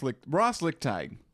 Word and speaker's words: Lickteig: No, Ross Lichtig. – Are you pronouncing the G? – Lichtig there. – Lichtig. – It kind of disappeared in the throat.